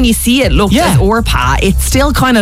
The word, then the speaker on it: Orpah: It's basically the O and the P are switched, pronouncing it Orpah.